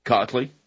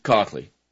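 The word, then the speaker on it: Kochly: In March 17, 2009, presentation before a county board of supervisors over proposed budget cut, District Attorney Robert Cockley, K-O-C-H-L-Y, I believe that would be pronounced Cockley,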